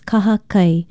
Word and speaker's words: kahakai: Aloha, I'm Leilani Poliahu of Hawaii Public Radio, and our Hawaiian word for today is kahakai. Kahakai is spelled K-A-H-A-K-A-I, and it means beach, seashore, sea coast, seaside, or strand.